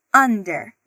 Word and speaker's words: under: Apesar de começar com U, a gente pronuncia under.